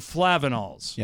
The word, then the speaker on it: flavanols: Is it flavanols? F L A V-A-N-O-L-S. – Sounds right.